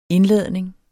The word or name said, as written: indladning